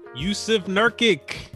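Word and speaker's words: Jusuf Nurkić: Yusuf Nurkic.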